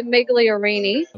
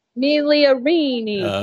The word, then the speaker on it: Migliorini: And I say, well, I'm from the South, so I pronounce the G, which here they're like, oh my God, like, what's wrong with you? So I say Migliorini.